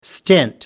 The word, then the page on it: stint: American English